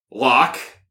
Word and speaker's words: Locke: Locke, like the philosopher. L-O-C-K-E. Mm-hmm. Piratson. P-Y-R-A-A-T-S-E-N.